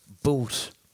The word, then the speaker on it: Butte: he fucked off to America and moved to a place called Boot, which I thought it was called Butt, because it's spelled Butt. – Yeah. – I said Butt on a podcast, and then a lot of people from Butt got onto me and said, don't be calling it that.